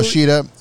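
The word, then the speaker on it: Shida: I'm going to go with Sheeta. We're going to call it Sheeta. It's easier to say, and it's actually the correct way to pronounce it.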